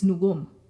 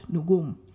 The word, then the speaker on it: nugum: So for example, when you're speaking about your own grandmother, it's nugum. Okay, notice two syllables, emphasis on the last, nugum.